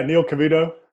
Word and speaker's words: Neil Cavuto: Yeah, Neil Cavuto.